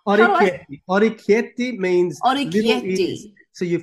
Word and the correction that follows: orecchiette: So orecchiette.